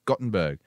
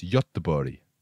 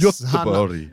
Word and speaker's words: Göteborg: So people always say things like, oh, you should go check out Gothenburg. But it's like, yeah, well, I have to get on a fucking plane. But it's pronounced for the Scandies or the Swedish people out there, it's Göteborg. Or the non-Swedish, I should say.